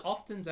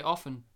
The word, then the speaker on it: often: No, I think often's actually right because it's a T. – Oh, yeah, but people will more commonly say often. – But there's a letter in there. – There's such thing as silent letters.